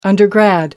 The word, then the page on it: undergrad: American English